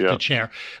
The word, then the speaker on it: Dethier: his last name is nothing like DeCher. It's actually DeTHIER, but it's pronounced DeCher.